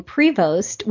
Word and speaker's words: Prevost: And so now a great way for people to remember how to spell it is Mikela, M-I-K-E-L-A. And I've had a, for my last name, Prevost, which is French, I've heard a little kid say it on accident, pre-toast.